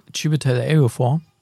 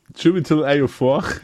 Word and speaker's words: Chiwetel Ejiofor: Chiwetel Ejiofor. – Chiwetel Ejiofor.